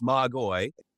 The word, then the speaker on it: magoi: So in the Greek, it's Magoi.